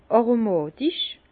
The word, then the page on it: aromàtich: Bas-Rhin; Herrlisheim